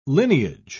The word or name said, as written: lineage